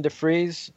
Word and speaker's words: DeFries: And I want to tie in his partnerships, because without Tony DeFries on the business side, is that the right pronunciation, DeFries? – Yeah. – Yep.